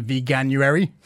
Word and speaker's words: Veganuary: So it's effectively about veganism and veganuary, if I'm saying that correctly.